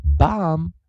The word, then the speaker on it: bomb: 希望同学们在 bomb 以及 com 这两个啊，以及我们记得结尾的 mb 啊，我们的 coda mb 的时候 b 是不发音的。